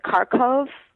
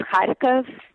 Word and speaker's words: Kharkov: I guess Americans maybe pronounce it Kharkov. – Okay. – In Russian, it's Kharkov. Kind of a yeah. – Nice phlegmy sound at the beginning, but it's like K-A-K-H-A-R-K-O-V.